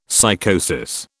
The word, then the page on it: psychosis: British English